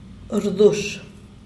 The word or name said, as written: rdush